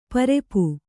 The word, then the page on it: ಪರಪು: kannada